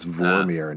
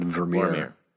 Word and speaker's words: Vormir: We said it wrong on a previous podcast. It's Vormir, not Vermeer. Vormir. Vermeer is the painter, the guy who did the...